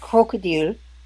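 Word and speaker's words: Krokodil: It's actually pronounced crocodile with a K because it's Siberian.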